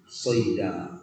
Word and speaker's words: صيدا: وخالد صاد الغداة صيدا، مثال وخالد صاد الغداة صيدا، الشاهد صيدا، وقف على صيدا، فتقول صيدا ولا تقول صيدا وأنت واقف، هذا لحن في النطق، وإن لم يكن لحنا في الضبط النحوي لكن لحن في النطق، فتقف عليه بالألف. نعم صيدا، واقف وأنت واقف.